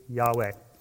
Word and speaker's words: Yahweh: and the pronunciation to be Yahweh. I think I'm saying that right. I'm not a Hebrew guy. So is that close enough, Dr. Hankins? – Right on.